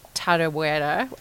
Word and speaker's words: Tarawera: I raced Tarawera, which I'm going to call the pronunciation of.